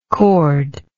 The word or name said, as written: cordlike